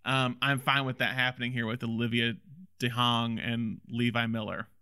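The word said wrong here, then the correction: DeJonge: I keep like I'm, I'm mispronouncing her name every DeYoung. time. It feels DeYoung. DeYoung. It's it's it's. Uh, I saw an interview with her. She said it's it's like Scandinavian, like like Nordic.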